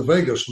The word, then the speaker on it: vagus: And a practice of yoga and meditation really stimulates the vagus nerve, spelled V-A-G-U-S, but pronounced like Las Vegas, which then distributes that Walgreens in our brain all the way from head to toe.